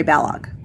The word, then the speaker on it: Balogh: So, yes, and the new Mary Balogh, I don't know if it's Balogh or Balogh. I apologize if I mispronounced it. It rhymes with Kellogg, so Mary Balogh.